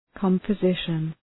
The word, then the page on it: composition: {,kɒmpə’zıʃən}